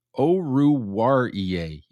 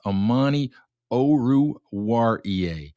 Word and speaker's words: Oruwariye: Oruwariye. Yes, I'm going to have to practice how to pronounce that one, but that is how you pronounce it. It's Amani Oruwariye.